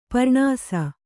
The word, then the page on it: ಪ್ರಾಣಿಸು: Kannada